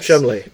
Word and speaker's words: Cholmondeley: Yes, it is Chumley. – But it's spelled as you would think to be pronounced Cholmondeley.